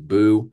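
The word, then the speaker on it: Buh: B-U-B-U-H, just pronounced Boo.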